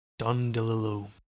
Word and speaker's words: DeLillo: Don DeLillo. – Rhymes with... – Brillo, like a Brillo pad.